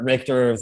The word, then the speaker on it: Richter: Richter, thinking of earthquakes, rhymes with mixture.